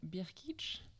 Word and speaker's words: Brkic: Donc, c'est le domaine Birkitch. Ça fait 3 mois que je travaille sur la prononciation du domaine. Pour les plaies, c'est B-R-K-I-C.